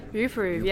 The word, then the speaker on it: YouProve: YouProve, yes. Yeah. So it's written with a U, but it's just pronounced YouProve.